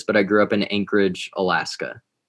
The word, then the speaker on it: Anchorage: And living in, how do you pronounce it, Anchorage? – Anchorage, like an anchor.